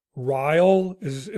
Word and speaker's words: Ryall: Today, my guest is Mark Ryall.